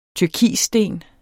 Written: turkissten

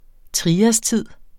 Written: triastid

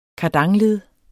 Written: kardanled

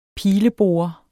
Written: pileborer

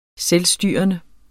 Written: selvstyrende

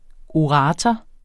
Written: ORATORER

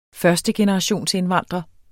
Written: førstegenerationsindvandrer

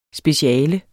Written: SPECIALETS

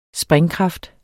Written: springkraft

